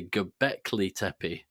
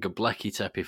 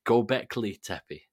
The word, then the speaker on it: Göbekli Tepe: It's actually Göbekli Tepe, and I've been pronouncing it Göbekli Tepe for some reason for ages. So I just wanted to, there may be people listening to my pod that have heard me mention that and thought, why is he saying it like that? And I just wanted to throw that out there. I know the error of my ways, and I'm now saying it correctly.